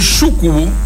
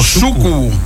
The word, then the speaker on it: súcubo: ¿El súcubo o súcubo? – Súcubo. – ¿Qué súcubo? – La palabra esdrújula. Esdrújula. Súcubo. Con acento en la U.